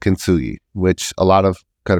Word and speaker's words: Kintsugi: K-I-N-T-S-U-G-I. Kintsugi.